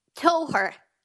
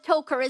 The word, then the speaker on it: Toher: Toher. Although the locals do call it Toker as well, in fairness.